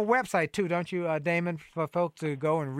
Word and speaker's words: Daymond: So uh, my, my name is spelled Daymond as in Raymond with a D.